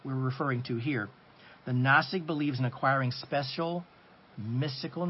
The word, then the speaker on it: Gnostic: Let's define the term Gnostic. G-N-O-S-T-I-C. It's Gnostic. The G is silent. The G is always silent.